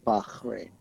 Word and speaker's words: Bahrain: It's Bah. – I like to say Bach. Bahrain. – So kind of like take J.S. Bach and then say Bahrain. – Like Bach. – Yeah, Bahrain.